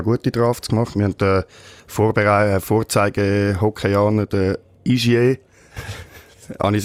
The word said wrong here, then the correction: Hischier: Richtig muss man glaube ich sagen Hischier, weil er ein Oberwalliser ist.